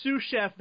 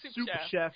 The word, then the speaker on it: sous chef: The sous chef that she thinks is pronounced soup chef.